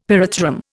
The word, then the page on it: pyrethrum: Indian